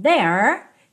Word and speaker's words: there: Nunca diga there, there, there. Não, o último E não é pronunciado. Então, there termina ali, ó, com a língua enrolada, igual na porta, com a perna esquerda.